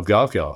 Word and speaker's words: Gavekal: And I want to compliment you on your pronunciation of GovCall. Hardly anybody gets that right. So it has to be your French background.